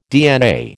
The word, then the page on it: DNA: American English